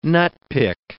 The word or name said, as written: nutpick